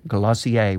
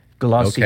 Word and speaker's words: Glossier: which is pronounced, Glossier. – Okay, all right. – I had to look that one up. I know you were doubting that, right? – I was going to say glossier, so I'm glad you said that.